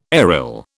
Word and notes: aryl: British English

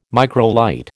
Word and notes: microlight: American English

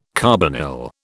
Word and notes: carbonyl: British English

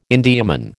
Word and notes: Indiaman: American English

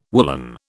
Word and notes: woollen: British English

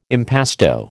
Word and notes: impasto: American English

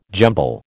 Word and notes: jumble: American English

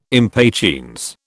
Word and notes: impatiens: British English

